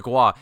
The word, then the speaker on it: Gua: Well, it opens up with a parcel being delivered to Joshua from the Gua. Am I saying that right? The Gua homeworld? – The Gua. It's like guac without the C.